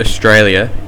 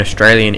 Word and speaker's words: Australia: The word Australia in Australian English is pronounced as either Australia, Australia, or Australia.